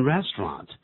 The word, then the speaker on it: restaurant: 在这里的 restaurant, 但是不要这个小看这个餐厅啊，它有两种发音，一个是念成 restaurant, 另外是念的比较慢，它就变成 restaurant, 所以我们请来 Bruce 做个示范。